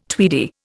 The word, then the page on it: tweedy: Indian